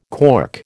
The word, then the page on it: cork: American English